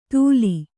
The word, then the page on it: ಧೂಲಿ: Kannada